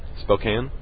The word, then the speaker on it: Spokane: Well, how do you pronounce that damn city again? – Spokane. – That sounds like the way I was saying it, and I got mocked and ridiculed for saying Spokane. – Spokane. – Spokane. K-A-N-E. The silent E means the A is long. That's what I learned in grade school. But whatever. Spokane. I'll try to remember that.